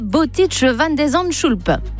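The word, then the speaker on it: Botic van de Zandschulp: Le Français affronte ce soir à New York le 70e mondial, le Néerlandais Boutic van de Zandschulp.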